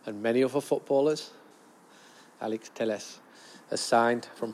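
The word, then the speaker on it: Telles: As I'm saying this, I'm getting pings to say that Teles, Alex Teles, that's how you pronounce it in Portuguese, Teles, he's from the Rio Grande do Sul in the south of Brazil.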